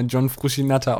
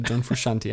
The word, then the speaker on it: John Frusciante: Ähm, ja, der hat ihn immer John Frushi Natter ausgesprochen. Fand ich mal sehr lustig. Aber nee, John Frushanti heißt er eigentlich.